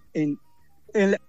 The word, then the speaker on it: Huawei: Y Ubei es el principal operador Huawei. – ¿Cómo se pronuncia? – Huawei. – No te escuché. ¿Cómo se pronuncia? – Huawei. – Bueno, Ubei se escribe. Huawei.